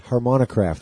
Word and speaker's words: Harmonicraft: Harmonicraft, that's how you say it. I had trouble pronouncing it.